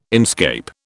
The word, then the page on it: inscape: British English